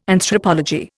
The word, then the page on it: Anthropology: Indian